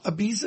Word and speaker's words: Ibiza: New York to Ibiza? Ibiza, is that how you pronounce it? – Yeah.